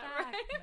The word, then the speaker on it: Backman: So this month we decided to do Beartown by Frederick Bachman. Did I say that Bachman.